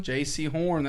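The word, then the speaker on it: Jaycee Horn: How you say his name? – Say it. – J.C. Horn. – J.C. Horn. That's it. – J.C. Horn. That's how you say it.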